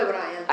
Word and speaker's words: Bryant: A questo proposito ci piace leggere una lettera che lei scrive questa Mrs. Bryant, che così, non so se si pronuncia così in inglese. – Sì, Anita Bryant.